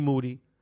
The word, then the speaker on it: Moody: It's G Monetti, G Moody. Last name rhymes with duty, embarking, christening the ship that will eventually go out into the sea on its own. And I am G Moody with This, That, and the Third.